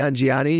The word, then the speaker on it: Nanjiani: Nanjiani, yeah.